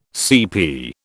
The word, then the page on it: CP: British English